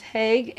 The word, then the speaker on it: HIAG: is pronounced Haig, H-I-A-G. You could reverse it Haig.